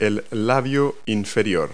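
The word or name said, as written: lower lip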